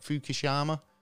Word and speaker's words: Fukushima: Fukushima.